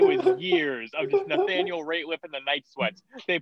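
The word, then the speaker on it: Rateliff: I don't know if it's Nathaniel Radcliffe in the night sweats. It's Nathaniel something in the night sweats. Ratliff, not Radcliffe, Ratliff.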